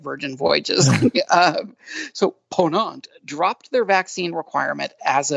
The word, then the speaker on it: Ponant: Luxury cruise line Ponant, and am I pronouncing that right, Doug? Is it Ponant? – It's a Ponant. – Ponant. Okay.